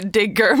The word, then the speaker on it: Digger: Men nu tar han klivet från action till mörk satir i Digga. En film som kan bli en historisk flopp. Eller är det Digger? – Det är Digger, men jag föredrog ditt uttal.